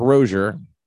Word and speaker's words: Rozier: Well, Clark Rozier, rhymes with Dozier, was rushed to the Kings County Hospital in Vineland, Brooklyn, where she was pronounced dead.